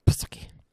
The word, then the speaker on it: Psaki: I definitely think the P is silent. – It's got to be Psaki.